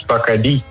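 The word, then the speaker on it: Bacardí: Nee, want het is dus een Cubaans merk. En er staat inderdaad een accentje op de i als je goed kijkt. En dan spreek je dat dus uit als Bacardi.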